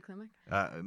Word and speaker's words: Klimek: Is that how you say her name? – Oh, yeah, Tilly Klimek.